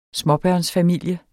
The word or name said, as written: småbørnsfamilie